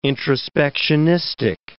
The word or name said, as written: introspectionistic